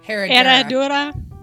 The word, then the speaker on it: Herradura: Herradura. – Herradura.